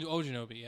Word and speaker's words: Ogunjobi: Yeah, I thought particularly Reader and Ogunjobi. Is that how you say it? – Yeah, in Ogunjobi, yeah.